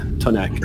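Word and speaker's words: T'onak: Kak? Kak? – Tonak? – Tonak. – Tonak. – T-apostrophe-O-N-A-K. It's okay. It's how it's pronounced, yeah. Tonak.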